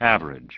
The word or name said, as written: Average